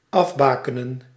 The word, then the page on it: tracer: France; [tʁa.se]